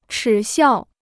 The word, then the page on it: 耻笑: chǐ xiào